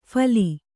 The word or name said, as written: ಫಲಿ